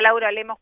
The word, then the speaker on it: Laura Lemos: Pero lo que sí tenemos en este momento es a Laura Lemos, coordinadora a nivel de Latinoamérica de Semillero de Líderes.